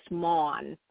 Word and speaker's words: Maughan: My last name is pronounced Maughan. It's actually my husband's last name, but you were very good at pronouncing it.